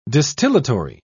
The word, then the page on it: distillatory: British English